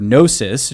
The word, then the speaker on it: gnosis: The word gnosis, G-N-O, as Sean put it, the silent G, is the Greek word for to know or knowledge.